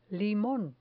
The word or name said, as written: limon